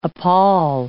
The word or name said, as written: appall